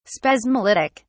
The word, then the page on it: spasmolytic: British English